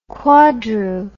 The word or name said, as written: quadru